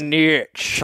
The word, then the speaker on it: niche: It's pronounced niche. – Thomas is sounds all right. Here we go. Sounds like N E E S H S H niche.